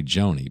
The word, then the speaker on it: Joni: It's spelled Joni, and so you want to say Joni, but she pronounces it Joni, J-O-N-I.